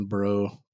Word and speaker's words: Breaux: I wanted to name my son Bro B R E A U X.